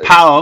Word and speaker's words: PAOK: PAOK, that's how you pronounce it, yeah.